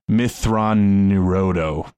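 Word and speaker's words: Mitth'raw'nuruodo: Thrawn, it's Mithran Nerodo is how you pronounce his name.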